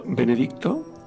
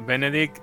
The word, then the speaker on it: Benedict: Ostras, es que Benedict Cumberbatch. – Benedict Cumberbatch. – Efectivamente, hoy lo he dicho bien. Benedict, ahí hay que pronunciar la T al final.